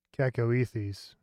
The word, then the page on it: cacoethes: /ˌkækəʊˈiːθiːz/; English